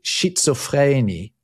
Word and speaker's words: Schizophrenie: So he talked about schizophrenie, right, is the German pronunciation of it.